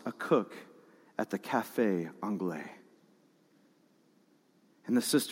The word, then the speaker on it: Anglais: A visiting general exclaims he has only ever tasted food like this at the famous Café Anglais in Paris. I know, you don't pronounce the S.